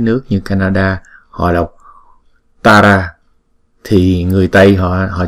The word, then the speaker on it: Tara: Còn khi chúng ta đọc tiếng Anh á, người ngoại quốc người ta đọc chữ T này là tờ, Waitara, Waitara. Không có làm như chữ thờ vậy đó.